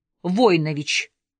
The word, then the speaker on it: Войнович: Вот, познакомьтесь, Владимир Войнович. Сац уже знал, что мою фамилию следует произносить с ударением на первом слоге.